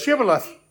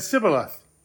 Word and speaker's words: Shibboleth: Those from Gilead said Shibboleth. Those from Ephraim said Sibboleth. They couldn't say the SH sound.